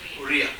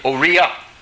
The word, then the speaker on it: Oriya: They're working on it in Ori, is that the proper word? – Oriya. – Oriya. That's the language of Orissa.